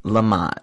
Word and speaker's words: Lamott: Wait, your last name is pronounced Lamott, right? – Correct. Oh my God. Like the applesauce.